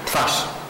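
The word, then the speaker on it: Twarz: the original title, Twads, am I also killing the pronunciation? – Twas.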